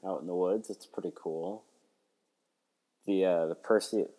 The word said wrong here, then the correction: Perseid: Perseid, I believe it's pronounced meteor showers tonight, like the peak of it, but it's supposed to linger on through the weekend.